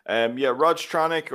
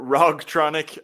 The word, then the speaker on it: Rogtronic: Rogtronic, or Rogtronic, as most people pronounce it. My name is Roger, but you know, when you spell out Rogtronic, you'll pronounce it Rogtronic.